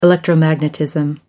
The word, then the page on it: electromagnetism: American English